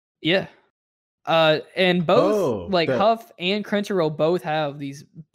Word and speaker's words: HUF: We got this Godzilla X Huff. Is that how you say it? – Yeah. – HUF Huff.